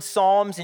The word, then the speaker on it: Psalms: looking at that word Psalms and you're wondering why in the world there's a P there, it's like a silent P, and you're like, what's happening, right?